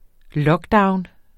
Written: lockdown

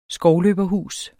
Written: skovløberhus

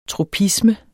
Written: tropisme

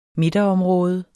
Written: midterområde

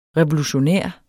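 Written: REVOLUTIONÆRT

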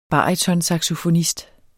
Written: BARYTONSAXOFONISTER

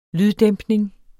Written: lyddæmpning

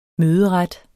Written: møderet

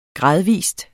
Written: gradvis

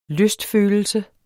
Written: lystfølelse

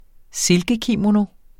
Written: silkekimono

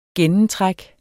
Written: gennemtræk

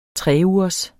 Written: treugers

